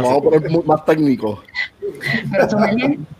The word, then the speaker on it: sommelier: Es una sommelier.